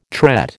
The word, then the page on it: tret: American English